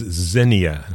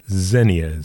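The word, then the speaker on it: Zheniia: Her name is Zenia, and I will spell that for you. It's Z-H-E-N-I-I-A. Zenia is her name.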